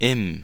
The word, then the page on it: im: IPA: [ʔɪm]; IPA: /ɪm/